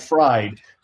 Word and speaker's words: Fried: And it's Fried. He spells it like fried, but it's pronounced Fried.